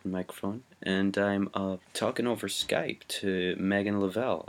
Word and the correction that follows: Lovell: I'm wondering, am I pronouncing that right? – It's Lovell. It's fine. – It's Lovell.